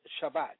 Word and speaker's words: Shabbat: The Hebrew word for Sabbath is Shabbat, pronounced Shabbat, meaning to cease, desist, rest.